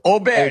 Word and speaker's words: Obear: He says, Obear, no hard G at the end. It is an O sound. So Obear, there we go.